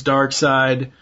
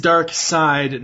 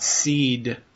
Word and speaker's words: Darkseid: as far as the actors, you've got Tim Daly as Superman, Michael Ironside, who is Darkseid, and it is Darkseid, not Seed, people. Yes. It's Darkseid. – It's even spelled Side. If you don't know that, you just don't know English. Sorry. – Yeah. Well, it's spelled S-E-I and not S-I.